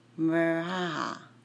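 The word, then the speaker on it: Mirjana: Mirjaja, like the J's are silent, so Mirjaja, if you want to pronounce it that way.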